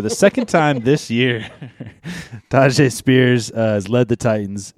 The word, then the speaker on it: Tyjae: Or Tajay. Am I saying that right? – Tajay, yeah.